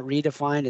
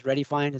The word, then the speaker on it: Redifined: It's not redefined, it's Ready Fine. Is that how they say it? – Yeah, you know what? Dewey and I were talking about it, and we agreed Ready Fine since it's more than ready and it's spelled REDI.